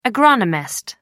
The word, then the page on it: agronomist: American English